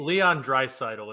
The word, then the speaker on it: Draisaitl: Leon Draisaitl is how you pronounce the name.